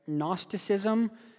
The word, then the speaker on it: Gnosticism: One of those false teachings was something called, let me pronounce this right, Gnosticism.